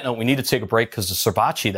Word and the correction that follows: ceviche: that Ollie made, did I say that right, Ollie? – How do you say it, Ollie? Ceviche.